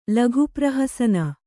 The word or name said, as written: ಲಘುಪ್ರಹಸನ